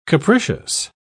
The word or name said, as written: capricious